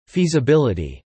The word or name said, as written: feasibility